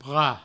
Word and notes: prat: provençal

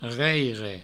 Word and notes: reireina: reireino

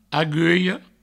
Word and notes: agulha: aguio

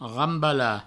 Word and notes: rambalar: provençal; rambala

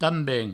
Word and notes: tamben: provençal

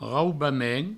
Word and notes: raubament: raubamen

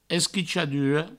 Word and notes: esquichadura: provençal; esquichaduro

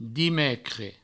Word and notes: dimecres: dimecre; Provençal

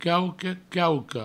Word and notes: quauque, quauqua: provençal; quauque, quauco

